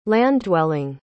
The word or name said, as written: land-dwelling